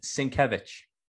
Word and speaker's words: Sienkiewicz: Bill Sienkiewicz, I want to say. – Oh my God. Sienkiewicz. – Sienkiewicz. Okay. – Yes, I think he's a Polish Jew, actually. – Okay. – Yeah. So Bill Sienkiewicz is a phenomenal artist.